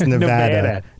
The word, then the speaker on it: Nevada: We mispronounced Nevada during the pre-chat. – And, Nevada. – Nevada. – Very Nevada. – Bostonian. – It's Nevada. Nevada. I'm going to Nevada.